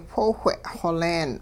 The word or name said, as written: whoowhe' xoleen